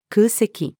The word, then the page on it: 空席: female